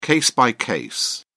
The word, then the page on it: case-by-case: British English